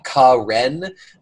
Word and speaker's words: Karen: named Ka-Ren, but it is spelled Karen, which just tickled me to death.